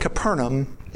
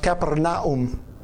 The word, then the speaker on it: Capernaum: And when Jesus was entered into, we call it Capernaum, it's actually pronounced Capernaum.